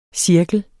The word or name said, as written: cirkel